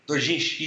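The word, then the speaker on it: Dojinshi (同人誌): É Doshinji? Doshinjin? – Dojinshi? – Dojinshi, isso, Dojinshi.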